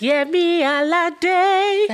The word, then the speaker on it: Yemi Alade: I want you to say it how you say it in the songs. It's Yemi Alade.